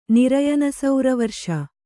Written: ನಿರಯನಸೌರವರ್ಷ

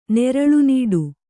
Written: ನೆರಳುನೀಡು

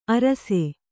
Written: ಆರೈಸು

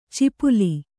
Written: ಚಿಪುಲಿ

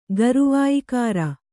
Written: ಗರುವಾಯಿಕಾರ

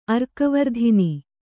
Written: ಅರ್ಕವರ್ಧಿನಿ

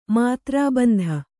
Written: ಮೂತ್ರಬಂಧ

